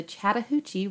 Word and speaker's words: Chattahoochee: Columbus, Georgia was founded in 1828 near the Chattahoochee River.